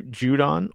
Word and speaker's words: Judon: Matthew Judon or Judon. – Judon. – As Chris Collinsworth kept saying last night. Somebody give this guy a pronunciation. Let me just go off real quick. Somebody give these guys a pronunciation sheet. Like, you know, when we used to do games, right? College, you see the pronunciation there. Now, sometimes I got it wrong before that. But Judon. Okay. Matthew Judon. Not Judon. It's not a capital D-O-N.